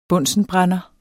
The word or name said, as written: bunsenbrænder